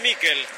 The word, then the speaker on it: Miquel: ¿Cómo le decimos? ¿Michael o Miquel? Usted que es especialista en nombre. Se escribe Miquel. ¿Cómo le digo? – Decirle Miquel. – Entonces le digo a Miquel, usted me enseña y yo aprendo.